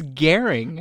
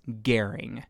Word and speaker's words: Gehring: But if you really want to know, it's Gehring. Kind of like overbearing or something.